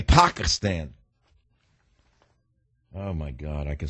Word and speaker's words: Pakistan: Americans say Pakistan, not Pakistan.